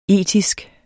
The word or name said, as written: etisk